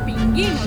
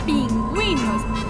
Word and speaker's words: pingüinos: Para indicar que se debe pronunciar la vocal U en las combinaciones G y G. A ti no te da vergüenza, te da vergüenza. Tú no vendes pinguinos, vendes pingüinos.